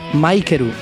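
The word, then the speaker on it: Maikeru (マイケル): Michael en japonés se pronunciaría Maikeru y micro Maikuro.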